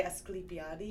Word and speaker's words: Asclepiades: Asclepiades. – Asclepiades, yeah. – Asclepiades. – But if you're Greek, you would say Asclepiades.